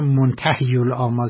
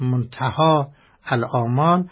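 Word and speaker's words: منتهی الآمال: البته بعضی ها که توجه ندارم منتهی الامال تلفظ میکنن که صحیحش منتهاست، منتها الامال.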